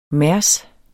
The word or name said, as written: mærs